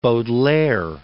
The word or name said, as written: Baudelaire